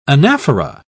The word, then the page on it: anaphora: British English